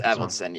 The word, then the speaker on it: Avildsen: They thought it slowed it down, but director Avildsen, is that how you say his name? Avildsen? – It's Avildsen, yeah.